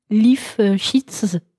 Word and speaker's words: Lifshitz: Alors, cette année, la carte blanche, c'est à Sébastien Lifshitz, c'est ça? Lifshitz. – Qu'est-ce que tu me racontes? – Lifshitz. – Pourtant, c'est pas anglais, là.